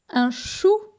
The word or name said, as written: Un chou